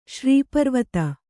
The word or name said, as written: ಶ್ರೀಪರ್ವತ